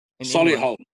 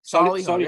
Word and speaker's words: Solihull: Solihull. – Solihull, yep.